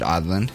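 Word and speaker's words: Aadland: He said Outland.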